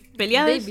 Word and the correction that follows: Paulides: ¿Cómo se dice su apellido? Paulides.